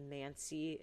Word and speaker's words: Mansi: You know what's crazy here, is that some of these photographs have the Mansi, am I saying that correctly, indigenous group.